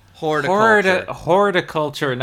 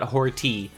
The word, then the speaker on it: horticulture: Horticulture, not horty.